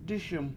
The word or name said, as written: dIS1m